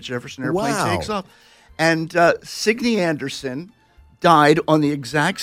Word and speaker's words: Signe: Signe? – That's right, Signe. It was it's a Danish name, and they pronounce it Signe.